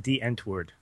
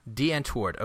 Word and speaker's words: Die Antwoord: A very good job, I might say and South African electronic rap duo Die Antwoord. – And it's I, actually, well, I think it's pronounced D Antwoord. D Antwoord.